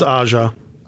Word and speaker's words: Aja: This was directed by Alexander Aja, I don't know how to say it the right way. – It's Aja. – Aja, okay.